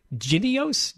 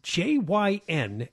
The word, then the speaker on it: Jynneos: Now that we have a few cases in the United States, it's pronounced Jynneos, Jynneos.